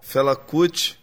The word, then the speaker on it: Fela Kuti: Fela Kuti. – That would be Fela Kuti from Nigeria, pronounced in typical Brazilian fashion, with the last syllable almost disappearing.